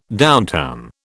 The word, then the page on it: downtown: British English